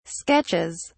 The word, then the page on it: sketches: British English